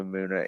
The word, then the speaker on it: Muna: Which is M-U-N-A for listeners. If you're like me and don't know who Muna is, I don't know if we're pronounced. Mm-hmm. Maybe it's Munya. It's Muna. Okay.